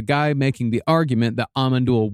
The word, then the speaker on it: Amon Düül: Actually, the correct pronunciation is Amon Düül. – Oh, fuck. Okay, yeah. From now on, Amon Düül.